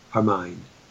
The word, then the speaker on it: Hermine: They just have H-E-R-M-I-N-E, Hermine. – Yeah, Hermine, exactly. Hermine. Let's see if Google, how do you, Google may do it, pronounce H-E-R-M-I-N-E.